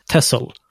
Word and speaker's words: Texel: Så ja, den, ja, heter, den heter, ja, det, det stavas Texel, det kanske du såg på det där. Ja, jo. Det, mm. uttalas, mm. det uttalas självklart Tessel.